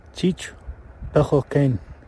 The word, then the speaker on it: Chich Pechokain: As you might recall, it is Bald Eagle House in translation, but here is audio of how to say it in the Sealch language. – Chich Pechokain.